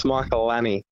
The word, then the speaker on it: Michalanney: What's the correct way of pronouncing your last name? Because a few people like to do it differently, including some commentators. You tell us, mate. – Yeah, I've heard all the different pronunciations. But the correct way to say it's Michalanney.